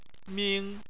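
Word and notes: ming1: tone 1

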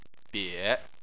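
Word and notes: bie: tone 3

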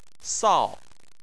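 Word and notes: sao: tone 4

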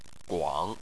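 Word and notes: guang: tone 3

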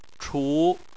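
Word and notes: chu: tone 2

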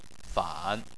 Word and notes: fan: 3

